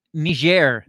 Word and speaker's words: Niger: Why does it matter that Niger, that's how you pronounce it too, Niger. It's not Niger, it's not Niger, it's not... – The other words. – The other word, it's Niger, apparently, according to Google and CNN and other companies.